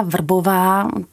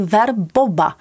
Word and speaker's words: Vrbová: No a když jsem ten konkurs vyhrála, tak pan režisér pak za mnou přišel a říká, hele, eh, jako Martina Vrbová, to je těžký pro Španěle vyslovovat, protože oni to vysloví ver boba. A ver je vidět a bobo, jako bobo, je blbec.